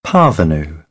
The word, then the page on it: parvenu: British English; ɑː